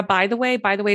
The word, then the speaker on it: Bytheway: I'm on Instagram at Laura, by the way, is my real last name and it's spelled just the way it sounds, BYTHEWAY.